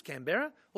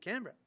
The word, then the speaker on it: Canberra: Three syllables, Canberra, or two, Canberra. The final government decision a triumph of pure political pragmatism was whatever pronunciation Lady Denman used on the day, that would be it. And perhaps because of her English origins, she opted for Coimbra.